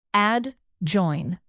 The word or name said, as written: adjoin